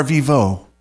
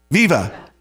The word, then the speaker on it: viva: Viva or vivo? Viva.